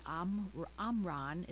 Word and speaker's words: Omron: Here are two of our top-rated monitors, and they list the Omron, it's spelled O-M-R-O-N, Silver Blood Pressure Monitor, and it's from Amazon.